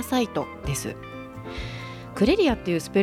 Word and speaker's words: CREREA: CREREA と書いてクレリアと読むんですけ